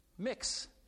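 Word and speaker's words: Mx: as well as the honorific mix, M-X, pronounced mix, as an alternative to Ms. and Mr., and which was also recently added to the English Oxford Dictionary.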